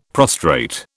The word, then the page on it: prostrate: British English